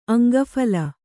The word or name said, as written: ಅಂಗಫಲ